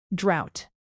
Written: drought

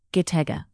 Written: gitega

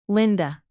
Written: linda

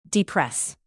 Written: depress

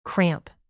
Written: cramp

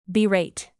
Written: berate

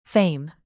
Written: fame

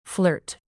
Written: flirt

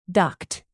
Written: duct

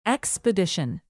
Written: expedition

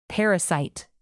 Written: parasite

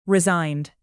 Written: resigned

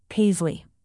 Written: paisley

